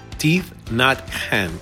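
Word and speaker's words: Thich Nhat Hanh: Llegó el momento de compartir esta metáfora se llama la metáfora de la lechuga del monje tith Nat Han. teeth Not Han. Creo que esta vez, esta vez le atiné a pronunciarlo correctamente.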